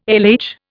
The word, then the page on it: LH: Indian